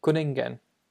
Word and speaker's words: cwningen: Quinnigan. – It's close. – Okay. – It's Kuningen.